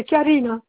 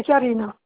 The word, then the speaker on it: Karine: Alors, mon nom, c'est Madame Barberin, et mon prénom, c'est Karine. Pour vous, c'est Charine. Pour vous, c'est Charine. Ça s'écrit Charine, pour vous. Hein. Normalement, c'est Karine.